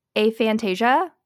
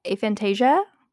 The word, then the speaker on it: aphantasia: So – if this is incorrect, it lied to me, but it's pronounced aphantasia, aphantasia. – Okay. – It's spelled A-P-H-A-N-T-A-S-I-A, aphantasia. – That's what we're going with.